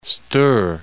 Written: Great Stour

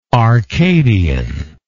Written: arcadianly

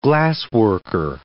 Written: glassworker

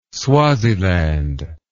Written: swaziland

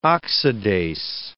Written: oxidase

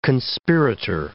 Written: conspirator

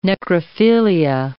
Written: necrophilia

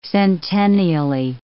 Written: centennially

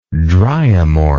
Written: dryomorph

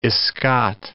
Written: escot

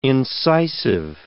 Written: incisive

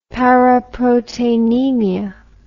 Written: paraproteinemia

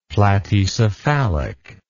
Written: platycephalic

